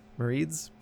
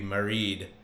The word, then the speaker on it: Marid: Marids. – I always hear it pronounced like married. – Married? – Like, yep. Like, married. It's almost like the past tense of marry, but like, right. Maybe Marid.